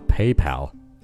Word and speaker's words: PayPal: 好，那上面还说到支付宝，那在国外也有支付宝的，它叫 PayPal。 PayPal，P A Y P A L，PayPal，那 Pal 就是伙伴的意思，那 PayPal 也就是支付的伙伴，那支付的伙伴就相当于支付宝了，在国外叫 PayPal。